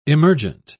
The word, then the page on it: emergent: British English